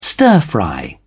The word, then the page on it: stir-fry: British English